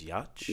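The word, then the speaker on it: Yauch: Rodney King, Whitney Houston, and Adam, I think you say his name is Yacht.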